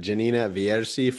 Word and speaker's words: Gianni Vierci: On today's episode, we have Gianni Vierci from Paraguay.